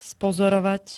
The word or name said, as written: spozorovať